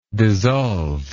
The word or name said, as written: dissolvingly